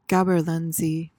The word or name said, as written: gaberlunzie